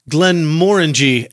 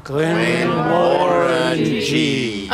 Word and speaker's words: Glenmorangie: Morangie, okay. – Rhymes with orangey.